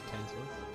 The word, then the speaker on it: Tantalus: No, it's phonetic. – Okay, cool. Good stuff. – Yeah, as in tantalizing, isn't it? – Ooh. – Yes. – Tantalus? Tantalizing? – I'm tantalized now.